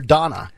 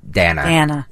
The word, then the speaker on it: Dana: Because he called Dana Bash there Donna. – Well, people say that she's... Dana. Is how she pronounced it.